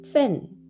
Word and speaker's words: 份: これ音は、フェン、フェンというふうに発音します。